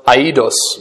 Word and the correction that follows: aidos: I'm sorry, ahidos.